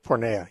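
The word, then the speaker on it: porneia: I'm not sure how to pronounce it, porneos, which means fornication. – Pornea, yeah. Mm-hmm. – Pornea.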